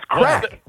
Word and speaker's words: craic: How is it pronounced? – I still don't think he knows. – It's crack.